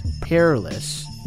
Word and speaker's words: Perilous: It's spelled Rebelutionists by the Ill Dynasty featuring Babyface Perils. Actually, no, I said that wrong. Babyface Perilous and Casablanca with cuts by DJ Can.